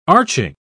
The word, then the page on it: arching: British English